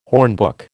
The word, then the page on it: hornbook: American English